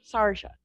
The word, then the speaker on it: Sharjah: what is it, Sahara? Is that how you pronounce it? – Sharjah. – International Book Fair in the United Arab Emirates. Again, another conversation for another day, but wow, so cool.